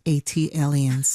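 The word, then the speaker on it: ATLiens: But it's pronounced AT aliens. – Ah, thank you. – Like Atlanta alien. AT alien.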